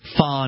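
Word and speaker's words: phở: which is P-H-O. So that's an Asian word. It's pho noodles. It's pronounced pho.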